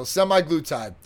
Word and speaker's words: semaglutide: So semaglutide.